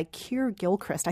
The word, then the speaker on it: Keir Gilchrist: The lead is played by Keir Gilchrist. I think I'm pronouncing his name wrong. Am I pronouncing his name wrong? – No, I think that's right.